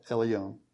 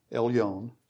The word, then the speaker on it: Elyon: Blessed be Abraham of Elohim Elyon, possessor of heaven and earth. And blessed be," and same phrase again, "God Most High." This word is E-L-Y-O-N, pronounced Yon, and it literally means made high.